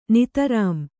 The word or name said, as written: ನಿತರಾಂ